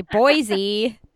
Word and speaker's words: Boise: So how long have you been back to, and I feel like I say Boise wrong. How long have you been back? Am I saying it wrong? – Boise with a C. – Boise.